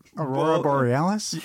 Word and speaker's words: Aurora Borealis: How do you say it? – Aurora Borealis. – Yeah.